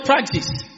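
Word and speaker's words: Praxis (πρᾶξις): That's the Greek word praxis. Is that the correct pronunciation? Praxis or praxis? Praxis, more like it.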